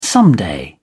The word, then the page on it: someday: British English